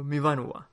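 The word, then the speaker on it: Myfanwy: se pronuncia algo así como, Mibanua.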